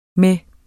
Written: med

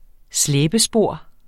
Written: slæbespor

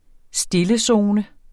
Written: stillezone

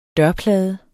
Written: dørplade

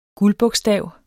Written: guldbogstav

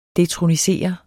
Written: detronisere

